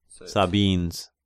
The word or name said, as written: Sabines